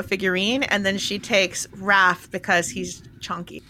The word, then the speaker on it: Hrafn: It's actually spelled H-R-A-F-N, but it's pronounced Raff.